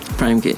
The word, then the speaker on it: Prhyme Kid: Did I say that right? – Prhyme Kid. – Prhyme Kid. P, yeah. Rhyme Kid.